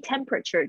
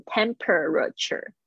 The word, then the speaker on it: temperature: 这个 body temperature 就是体温，temperature 不要念成 temperature，不要哦，no，那 temperature is temperature，你要那个 p，那很快的 temp，temp，然后 temperature，temperature，好的，我们继续，好，就是发烧不退啊，the fever。